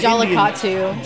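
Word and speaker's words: Jallikattu: But it's an Jollikatu, is how you pronounce it.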